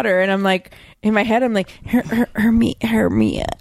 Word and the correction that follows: Hermione: And then, like, in the movie, it wasn't until the movie when I heard them say her name, and I was like, Well, and it's a French name, but it's actually pronounced Hermione.